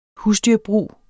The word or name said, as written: husdyrbrug